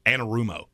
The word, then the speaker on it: Anarumo: Lou Amorosa, Amor Amor- Amoruso. Uh, no, you're adding a syllable. – Am I? Uh, it's Anarumo. Anarumo.